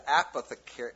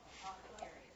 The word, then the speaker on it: apothecary: And we begin in verse 1 of chapter 10, and he says, dead flies cause the ointment of the apothecary. Is that how you pronounce that? – Apothecary. – Thank you. One more time. – Apothecary.